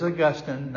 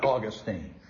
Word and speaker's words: Augustine: Howsomever, it is pronounced differently, and if you're talking about the saint, it is Augustine, not Augustine.